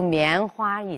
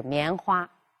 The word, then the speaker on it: 棉花: 比如“ 棉花” 一这个词，它在这个新闻语体里边，“ 花”。哎，尽量不要读作轻声，就是棉花。但是在对话和主持人节目中呢，这个花呢就可以读成轻声，棉花。这样呢就可以使语言更口语化。